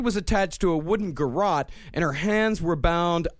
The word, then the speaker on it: garrote: how is this pronounced, garrote? – It's garrote.